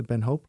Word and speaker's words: Ben Hope: Zeg ik het goed? Ja, is de Ben Hope.